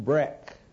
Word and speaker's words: Baruch: Breck, an allowable pronunciation of Baruch, because the A and the U are vowels not existing in the Hebrew language, who brought with themself a princess called Pharaoh's daughter.